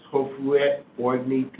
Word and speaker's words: Houphouët Boigny: If you know French real well, you can look at my writing and help me in pronunciations. He is very Houphouët Boigny. – Houphouët Boigny, you know that foundation? – Houphouët. – Houphouët. Boigny. Boigny. Merci beaucoup.